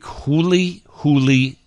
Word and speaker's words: huli huli: Somebody texted me and asked me did I know how to make huli huli chicken? I think that's how you say it. H-U-L-I, huli, huli, huli chicken.